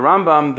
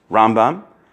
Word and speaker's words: Rambam: In the Rambam, the emphasis is on the right side of the word, Rambam, and Ramban, the emphasis is on the left side, Ramban.